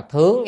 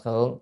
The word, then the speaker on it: thướng: Bởi vì thần thông có đó đó à, cái chữ này đọc là thướng chứ không đọc là thượng. Thướng thì mới là động từ đó, mình lên, đi lên. Mình đọc thượng á, là nó thành là gì? Phương vị từ mất rồi, không đúng rồi.